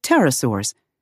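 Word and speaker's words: pterosaur: Quetzalcoatlus belongs to a large and diverse group of flying reptiles called pterosaurs, a name that comes from Greek words meaning wing lizards. The P in the word pteró is not pronounced.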